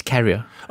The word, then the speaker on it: carrier: It's carrier. – Oh, carrier. Sorry. – Yeah, it's carrier.